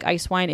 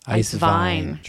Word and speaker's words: Eiswein: So they are out there, and the German ones, it sounds like Eiswein. It's spelled a little bit differently. So it is spelled Eiswein. So doesn't necessarily look the same as the words ice wine, but it is pronounced the same way.